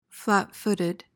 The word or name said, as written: flatfooted